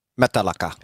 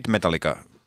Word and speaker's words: Metallica: Metallica. – No. Metallica. – Metallica. – Metallica. – Metallica. – It's like Lars Ulrich says it. – Yes. – The Danish way.